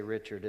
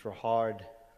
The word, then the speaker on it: Richard: You're going to want to say Richard. It's not Richard, it's Rahard and it's like Rumbrand.